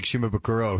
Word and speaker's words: Shimabukuro: If you're just tuning in, it's Jake Shimabukuro, who's on the show. I said it right that time, didn't I? – Yeah. Oh, that was perfect.